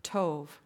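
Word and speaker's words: tov (טוב): It looks like a B on the end, but you pronounce it with a V, tov.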